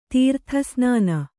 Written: ತೀರ್ಥಸ್ನಾನ

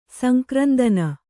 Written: ಸಂಕ್ರಂದನ

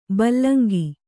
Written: ಬೆಲಂಕು

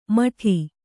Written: ಮಡಿ

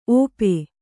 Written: ಒಪ್ಪ

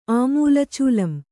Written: ಆಮೂಲಚೂಲಂ

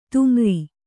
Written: ತುಮರಿ

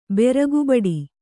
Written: ಬುರಗಿಬೊಟ್ಟು

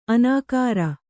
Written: ಅನಕರ

